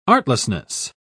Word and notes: artlessness: British English

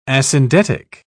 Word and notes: asyndetic: British English

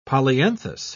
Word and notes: polyanthus: British English

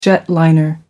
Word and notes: jetliner: American English

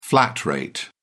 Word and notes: flat rate: British English

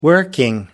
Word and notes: working: American English

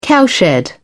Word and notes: cowshed: British English

